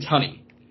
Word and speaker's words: Tunney: I mean, dude, you know who Gene Tooney is, like the old boxer from the 1920s. – Don't. – Okay, Gene, I may be pronouncing his name wrong. Gene Tunney.